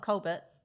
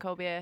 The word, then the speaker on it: Colbert: Colbert, yeah. It's Colbert, right? – Yeah. – It's not Colbert.